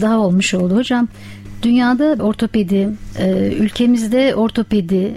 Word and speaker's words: ortopedi: Çünkü toplumda bunu birçok kere insanlar farklı telaffuz edebiliyor ama aslında kullanılması gereken kavram ortopedi kavramıdır.